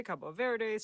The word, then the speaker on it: Cabo Verde: You and I are going with Portuguese, which is what people there would say. Cabo Verde, same country.